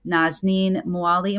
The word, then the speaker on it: Nazanin Moali: This is Dr. Nazanin Moali.